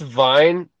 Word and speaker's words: Vine: So how do I say your last name? Is it Vane or it's Vine, but Vine. I've been called the wrong thing so many times I like don't have any attachment to the pronunciation of it.